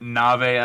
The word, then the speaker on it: Nave: There's a 50-50, you know. It's like Dave or is it Nave?